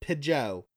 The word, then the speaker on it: Pidgeot: All right, and then her last comment was, and I always pronounce Pidgeot like Pigeot. It sounds Pigeot. Kind of French. Pigeot. It sounds French.